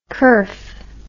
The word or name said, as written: curf